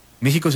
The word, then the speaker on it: México: México se pronuncia México. Y la historia de por qué se pronuncia México es de verdad muy bella, porque tiene que ver con la evolución del sh. Originalmente era México, de los mexicas, ¿no? Y luego se fue evolucionando hacia una J en vez de esa X, pero esa X tiene también como un símbolo.